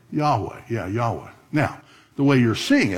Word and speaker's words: Yahweh: So it sounds like Yehovah, but it's actually the word Yahweh.